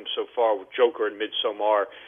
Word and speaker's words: Midsommar: Robert, tell me about I think it's pronounced Midsummer, but it's a Swedish film. It's spelled, summer is spelled S-O-M-M-A-R. Is it pronounced Midsummer? – No, it's actually pronounced Midsommar.